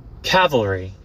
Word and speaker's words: cavalry: Yeah, it's Calvary. Calvary. Let's see. – Cavalry. – Cavalry. See, I'm learning. As I do this podcast, I'm over here learning with you guys. This is how you pronounce it.